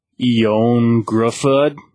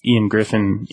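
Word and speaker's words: Ioan Gruffudd: I had to look up how to pronounce his name, because it looks like Ion Gruffudd, but it's Ian Griffin in America.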